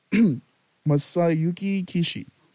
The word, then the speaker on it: Masayuki Kishi: But anyways, the next song for the show will be, it's called Modern Museum by Masayaki Kishi. I butchered her last name a little bit. That's my apologies. Masayuki Kishi. Modern Museum.